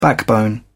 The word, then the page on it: backbone: British English